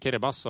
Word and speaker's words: Kiribati: Jeg hadde sagt Kiribati om jeg fikk valget, men det er ikke sånn det skal sies. – Nei, det er riktig. Og det uttales rett og slett Kiribas.